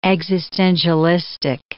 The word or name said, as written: existentialistic